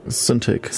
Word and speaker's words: Cintiq: Cintiq.